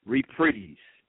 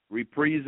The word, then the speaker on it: reprise: Reprise. Reprise is a noun, and it's spelled R-E-P-R-I-S-E. And now, when I saw this word, of course, R-E-P-R-I-S-E, I thought reprise, but it's the pronunciation is reprise. Reprise, okay?